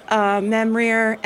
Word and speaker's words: Memrir: Memrier, M-E-M-R-I-R, Wizard's Bane. Spelled the usual way. – Spelled like it sounds.